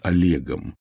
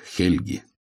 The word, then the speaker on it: Хельги: Эту великую миссию исполнил преемник Рюрика, которого русские авторы называют Олегом, хотя его имя произносилось как Хельги.